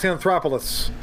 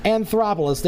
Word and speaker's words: Anthopoulos: Alex Anthopoulos. – Anthopoulos, there you go.